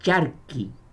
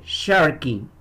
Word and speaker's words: Sharky: No es Sharky, como decir un charco pequeño, no. Es Sharky.